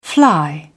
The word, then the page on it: fly: British English